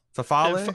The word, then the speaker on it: farfalle: It's like far farfalle, farfalle, farfalle, uh, farfalle.